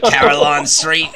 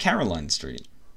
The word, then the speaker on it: Caroline Street: Hacks, why do you keep saying it with an Australian accent? You're like Carillon Street. – How should I say it? Say it again. – It's just Caroline Street.